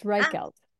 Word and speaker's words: Reichelt: It's a K sound, so it's Reichelt.